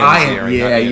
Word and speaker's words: Ziering: There was also Ian Ziegler, or whatever. I can't pronounce his name. – Ian Ziering. He pronounces it Ian Ziering.